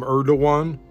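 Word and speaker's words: Erdoğan: Everybody calls him Erdogan because I guess the G is not pronounced like we do.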